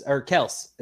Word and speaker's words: Kelce: Yeah, no, it's Kels.